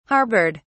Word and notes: harboured: British English

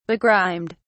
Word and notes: begrimed: British English

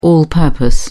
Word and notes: all purpose: British English

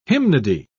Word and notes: hymnody: British English

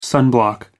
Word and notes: sunblock: American English

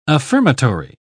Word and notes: affirmatory: British English

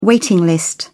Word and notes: waiting list: British English

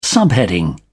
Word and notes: subheading: British English

